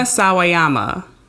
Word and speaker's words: Sawayama: Rena, Samayama? – No, it's Rena. – it's not Samayama? – No. It's Rena Sawayama. – I just said that. – You said say it again. – Sawayama.